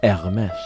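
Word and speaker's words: Hermès: Well, second we have Hermes, never to be pronounced Hermes, Hermes, or Hermes. It is Hermes.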